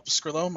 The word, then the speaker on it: Pescrillo: Well, you can find me on Twitter at Paul Pescrillo. My last name is spelled P-E-S-C-R-I-L-L-O.